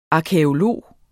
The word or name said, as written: arkæolog